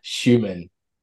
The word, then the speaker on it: Schumann: Is it Schussman or Schussman? How do I say his last name? – Schumann. – Schumann. That was bad.